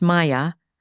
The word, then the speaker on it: Meijer: my great-great-great-grandparents' names are spelled M-E-I-J-E-R, pronounced Maya, Meyer, M-E-Y-E-R, and Meyer, M-E-I-E-R.